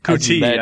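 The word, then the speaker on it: Coutee: Kuti, I said Kute. I was close.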